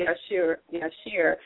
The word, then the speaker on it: Yeshua: This is the third person I've heard say Yeshua in the last days. That is the Hebrew pronunciation of Jesus.